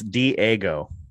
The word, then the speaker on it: Dago: So Dago, damn it, Dago, I'm sorry. – Pronounce it this way. It's Diego. – It's D-A-G-O is how he spells it. – It's Diego. I know. Pronounce it that way.